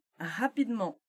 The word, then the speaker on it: rapidement: Se forem pronunciadas devagar, você ouve todos os us. Us? É, a gente pode falar us, né? – Sim, tá? sim. – Tá? – Já falando depressa, viram lentement e rapidement.